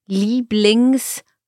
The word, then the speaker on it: Lieblingssport: So here pronunciation, Lieblingssport. – Ah, okay. Yeah? – Because although it's in the middle of the word, it's a compound noun and then we treat the Sport as if it were at the beginning.